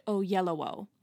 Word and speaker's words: Oyelowo: actually, it's pronounced David Oyelowo. So, I was wrong.